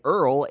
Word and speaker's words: URL: Chateau, that's a new one from Matt Bullard, who goes by Earl, a.k.a. URL. That was off of, you pronounce that Earl.